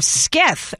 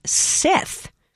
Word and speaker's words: scythe: It is actually pronounced Sith.